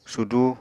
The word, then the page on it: sudu: Apatani